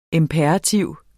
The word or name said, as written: IMPERATIVER